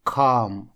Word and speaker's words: calm: Calm – спокойный. Спокойный, умиротворенный – это calm. Буква L здесь не читается в этом слове, а A читается звуком A – calm.